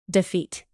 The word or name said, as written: defeat